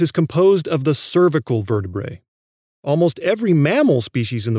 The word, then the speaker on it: vertebrae: I've pronounced vertebrae as vertebrae. That's because, in general, when you have a scientific word ending with the vowels A-E, you pronounce them as a long E, like the word algae, or like the many bird families I've talked about on the podcast.